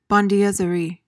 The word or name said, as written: bondieuserie